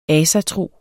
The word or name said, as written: ASATROEN